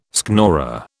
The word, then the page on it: schnorrer: British English